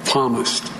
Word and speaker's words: psalmist: And if we do, and I'm sure we can proclaim the psalmist with the psalmist who wrote these following words. The Lord is my strength and my shield. – It's the peace. It's a silent P in the Holy Bible.